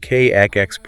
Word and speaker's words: CAAX: Which brings us to the cellular zip codes, specifically the science of KAAX proteins. It's spelled C-A-A-X. What actually is a C-A-A-X protein, and how does it manage these internal logistics? – Okay, so KAAX refers to a highly conserved sequence of four amino acids, and they're located at the very tail end of certain proteins.